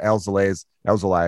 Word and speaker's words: Alzolay: But I believe I'd heard on the radio earlier that Cole Stewart was taking Adbert Alzolay, I should say. I always mispronounce his last name, spot.